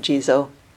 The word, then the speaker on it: Jizo: His name in Sanskrit is Kshitagarbha, Titsang in Chinese, but I will call him Jizo because it's easy and very familiar to me. His Japanese name, which also means Jizo, is the Japanese pronunciation of those two characters.